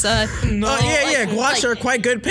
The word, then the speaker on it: gouache: Do you mean gouache? – Oh, yeah. – Guacamole. – I told you I was going to butcher it. – Definitely sounds like gouache.